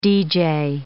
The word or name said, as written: DJ